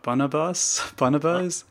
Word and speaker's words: Bonobos: I don't know how to pronounce the name properly, but Bonobos, is that right? – Yeah.